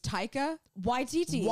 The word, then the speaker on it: Taika Waititi: I said Taika I said actually I, I feel like I actually didn't even pronounce Taika right. I think I said Taika Waititi though. I think I said Taika Waititi. It's Taika Waititi.